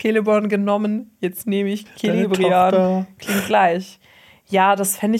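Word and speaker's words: Celebrían: Also so Celebrian. – Genau. Oder ja, Celebrian, ja, so kann man es aussprechen.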